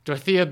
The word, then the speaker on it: Dorothea: Well, okay. It's not spelt, you know. – Dorothea. – Yeah, it's spelled Dorothea. Oh, is that her name, Dorothea? – Yeah. – Oh, well, then I said it wrong anyway.